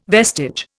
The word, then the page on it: vestige: Indian